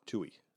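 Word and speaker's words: Twohy: Is it T U O Y? It's T W O H Y. Like Tui. Tui, there we go. That, that's how you pronounce it. T W H. No, Tui. It's Tui.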